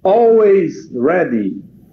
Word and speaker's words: Always Ready: Hours Ready. É isso? Always Ready. Muito bem.